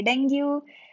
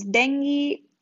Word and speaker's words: dengue: Some people say dengue. It's pronounced as dengue.